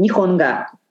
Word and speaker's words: Nihonga: Yes, the traditional style is called Nihonga. – Nihonga. Thank you. Nihonga. – Nihonga. It literally means Japanese painting.